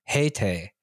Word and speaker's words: Haitai: No, it's because in it's because in English it's H A I T A I. Yeah, but in Hangul it's Heyte, Heite.